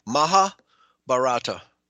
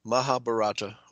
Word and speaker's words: Mahabharata: So, Mahabharata. Mahabharata. Okay. Maha is the first syllable, like mama. Mahabharata.